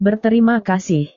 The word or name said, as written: berterima kasih